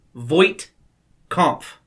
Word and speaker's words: Voight-Kampff: Voight-Kampff. Not Voight. Voight. Like John Voight, but spelled differently, right? – It's actually spelled, I think, exactly the same.